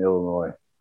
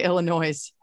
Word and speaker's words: Illinois: Ooh, when people pronounce the S in Illinois.